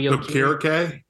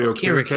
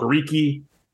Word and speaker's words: Okereke: I used to thought it was Okariki. That's how I thought it was, but I think he said in an interview once it's Okarike, if I'm not mistaken.